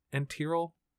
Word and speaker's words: enteral: This is total enteral nutrition. I think I'm saying that right. Enteral, E-N-T-E-R-A-L.